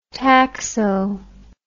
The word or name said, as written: taxo